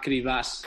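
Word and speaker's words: Kryvbas: Creo que no he pronunciado bien el nombre del club, ¿no? ¿Cómo se llama? – Bueno, a ver, yo tampoco. Se llama Kribas.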